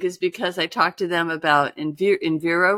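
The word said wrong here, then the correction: Envigo: Was that how you pronounce it? – Invigo. – Invigo. Yeah. Sorry.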